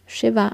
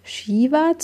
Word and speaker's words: Shiva: By the way, zur Aussprache von Shiva. Ihr hört mich die ganze Zeit schon Shiva sagen und ich muss sagen, das ist eines der Worte, die ich so oft übertrieben falsch ausgesprochen höre, aber das natürlich ohne Absicht, das vermute ich mal auch. Denn tatsächlich, wenn wir uns das Wort Shiva anschauen, dann haben wir dieses Sha, wir haben ein kurzes I, wir haben ein V und ein A.